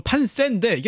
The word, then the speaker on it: 판세: 정식 발음은 판세인데 이게 참 이게 어색합니다.